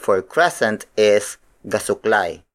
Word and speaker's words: gasuklay: The next shape is gasuklay. Gasuklay. Gasuklay. Gasuklay is spelled as G-A-S-U-K-L-A-Y.